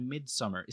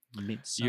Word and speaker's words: Midsommar: Is that how you say it? – Midsommar. You're the Swedish one here. – You should know. – You're the one who's supposed to tell us how to say it. – Okay, I'm just going to say Midsommar because that's the way I mean, it's literally spelled that way. But I'm going to say Midsommar from now on.